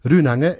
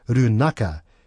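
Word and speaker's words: Rūnanga: And also similarly with Runanga in the North Island becomes Runaka in the South Island.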